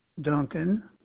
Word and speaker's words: Duncan: James Duncan, DUNCAN.